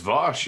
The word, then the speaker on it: Vaush: I have a guest for a little bit here, Vaush. I think it's Vaush. let me get his, turn on his mic for me. Is it Vaush? – It's Vaush, you nailed it.